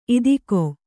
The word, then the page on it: ಇದಕೋ: kannada